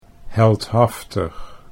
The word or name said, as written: heldhaftig